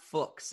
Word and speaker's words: Fuchs: but it's Scott Fuchs. It rhymes with books is the way to think about that.